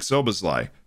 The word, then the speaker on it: Szoboszlai: On that team was a kid named Dominik Szoboszlai, who you probably have heard of before because you're listening to this podcast.